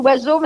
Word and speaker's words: Oizo: Parce Moi, je prononce Oiseau, mais ça s'écrit O-I-Z-O.